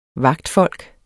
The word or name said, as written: vagtfolk